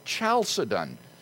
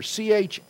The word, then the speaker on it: Chalcedon: Well, the debate came to a head in the year 451 at the Council of Chalcedon. Now, I would write that name on the blackboard, but I'll simply spell it for you since I don't have a marker. Chalcedon, Chalcedon, Chalcedon.